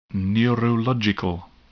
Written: neurological

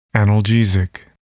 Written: analgesic

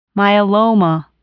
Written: myeloma

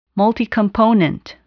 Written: multicomponent

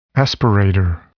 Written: aspirator

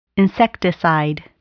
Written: insecticide

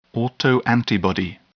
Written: autoantibody

